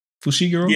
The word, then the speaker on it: Fushiguro: That's how you say that? Fushiguro? – Yes. – Fushiguro. Mm-hmm. There we go. I can read. – Fushiguro. I believe that is how you pronounce it.